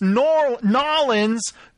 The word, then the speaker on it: New Orleans: First of all, if you live there, you should be able to say it right. It's Nolens, New Orleans.